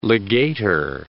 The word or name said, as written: legator